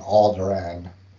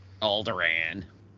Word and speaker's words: Alderaan: On Alderaan, as Obi-Wan pronounced it. – Alderaan.